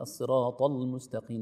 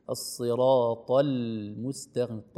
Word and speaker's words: الصراط المستقيم: سننطق الطاء بعدها اللام، نحذر من أن تؤثر الطاء على اللام، فنقول الصراط المستقيم، نقول الصراط المستقيم، طل الطاء مفخمة واللام رقيقة، نقول الصراط المستقيم.